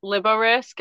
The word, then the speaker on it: Liborisk: Yeah, so it's it's actually pronounced Liborisk, and it's short for Liberty Risk.